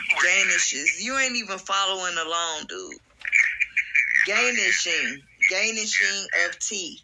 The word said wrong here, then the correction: gnashing: But the G is silent, so it's like, I don't know how to pronounce it, the gnashing of teeth. – Okay. Gnashing of teeth.